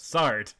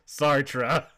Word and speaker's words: Sartre: And Lois says, yeah, but they didn't correct the interviewer on his pronunciation of Sart. And Malcolm under his breath mutters, Sartre.